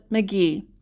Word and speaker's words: McGehee: We learned, through trial and error, that McGehee is not a three-syllable town like it's spelled. It looks like McGehee. That's not the case. Locals call it McGehee.